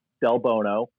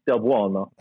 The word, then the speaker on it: Del Bono: Depends on who you ask, but if you ask me, it's Del Bono. But if you ask my mother, who needs the appropriate Italian pronunciation, it's Del Buono.